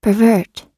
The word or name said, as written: pervert